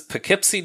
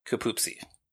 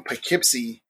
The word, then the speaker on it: Poughkeepsie: This is a town that I mostly associate with an episode of Bewitched, where the Stevens neighbor, Mrs. Kravitz, keeps trying to say Poughkeepsie, and she pronounces it Kapoopsie.